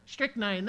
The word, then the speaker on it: strychnine: I think I'm saying that right. Strychnine, there it is. Ah, thank you.